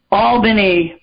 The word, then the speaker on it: Albany: Yes, because it's spelled phonetically Albany. – Okay. – But we pronounce Albany. – It Albany. Like there's enough, a double L in there.